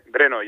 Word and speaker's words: Brno: No era contra un equipo de solteros y casados de Bro. De Brno. ¿Cómo se pronuncia esto? – Brno.